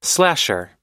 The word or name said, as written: slasher